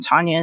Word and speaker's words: Tanya: So Maile is spelled M-A-I-L-E. My first name, Tanya, is T-A-N-Y-A.